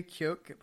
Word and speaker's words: Keoghan: Barry Keoghan. – Barry Keoghan. How do you spell that? – K-E-O-G-H-A-N.